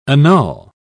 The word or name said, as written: annul